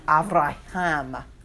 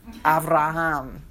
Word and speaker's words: Avraham: Abraham is pronounced Avraham. Avraham.